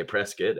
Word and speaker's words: Prescott: So maybe someone can, I said it Prescott at first and then I heard locals say Prescott. And so I've been saying it Prescott ever since.